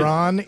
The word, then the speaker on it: Ron: You did not say my name correctly. It's right. Ron E. Raines. R-O-N.